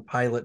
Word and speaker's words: Pilate: And then I have a mystery series called the John Pilate Mystery Series. There's 8 books. It's spelled P-I-L-A-T-E, like the Roman governor, not like the exercise instructor.